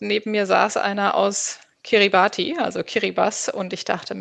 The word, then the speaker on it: Kiribati: Kiribati spricht man es aus in der Landessprache.